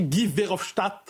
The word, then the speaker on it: Guy Verhofstadt: Guy Verhofstadt, l'homme politique...